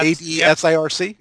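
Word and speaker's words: ADCIRC: They're doing I don't even sure how to pronounce this. It's ADSIRC. – Yeah. – ADSIRC. – Yep.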